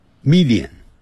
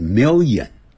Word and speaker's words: million: 我们呢很快会念成 Italian，它发脸的音，哎，不是 Italian 这样念 Italian food，好，一条眼好像念成一条眼，就像一百万啊，常常会听到我们老中念成的 million，不是在美式英语念成 million，没有眼睛。